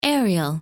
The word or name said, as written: Ariel